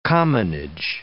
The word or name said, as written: commonage